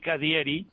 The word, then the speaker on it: cadierei: Se escribe Cadierei Cadieri.